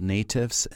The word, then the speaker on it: Natifs: So the nonprofit is called Natifs, and it's spelled N-A-T-I-F-S.